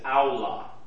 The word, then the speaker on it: aula: The word sheepfold, and I think I did put this word down, the word sheepfold is aula. A-U-L-A, we would spell it in English letters.